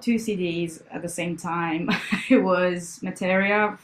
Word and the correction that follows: Meteora: from Materia. How do you pronounce it? – Was it Meteora? – Yeah, Meteora, I call it, from Linkin Park, and Amazing.